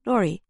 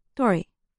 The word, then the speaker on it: dori: What is clear, though, is that the pronunciation of the word for street in Japanese sounds a lot like dori. It's pronounced dori.